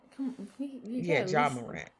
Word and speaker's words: Ja Morant: Jay Morant. – Ja Morant? – Ja Morant. – Pronounce his name.